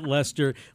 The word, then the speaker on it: Leicester: Well, and people who perhaps a week ago would have looked at the name and pronounced it Leicester now know all about Leicester City and the Foxes.